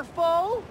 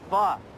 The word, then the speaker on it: pho: Would a Nazi's favorite snacks include ramen, pad thai, or pho? – Pronounced pho. Fuck, dummy.